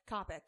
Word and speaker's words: Coppock: Is that how you say it? Coppock? – Coppock. – I had a panic as I was saying it that might be like Coppock.